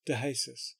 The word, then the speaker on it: DeJesus: It's spelt Mark DeJesus, J-E-S-U-S, but that's the pronunciation, DeJesus, on the subject of a root of rejection and mental health, exposing the rejection mindset.